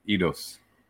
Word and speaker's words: Eidos: Eidos.